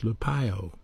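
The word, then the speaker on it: lupeo (λυπέω): The original word for grieve is pronounced lupeo, L-U-P-E-O, and it's a verb.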